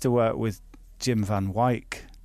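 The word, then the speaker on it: Wijk: So that W-I-J-K, I say Wyk.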